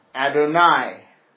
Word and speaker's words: Adonai: The Jews, in reading their scriptures, whenever that name occurs, whenever it shows up, YHWH, the Tetragrammaton, they always, always pronounced it Adonai. When Yahweh shows up, they pronounce it in the plural form. That's Adonai.